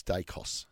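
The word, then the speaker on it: Daicos: And Pies legend Peter Dacus believes the minor premiers will lean on the side of... – No, Georgia. Amy, it's not Dacus. It's Dacos. – Does a great Dacos.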